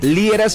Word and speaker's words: Liera: Gente bonita, si quieren contactar conmigo, mis redes sociales, en todas las redes, me encuentras por mi nombre, Fernando Liera, se escribe L-I-E-R-A.